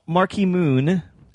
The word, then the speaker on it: Marquee Moon: So Marquee Moon, as I believe it's actually pronounced. – I believe you are right.